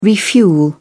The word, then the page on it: refuel: British English